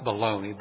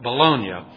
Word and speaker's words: Bologna: No Bologna. It's not pronounced Bologna, but Bologna.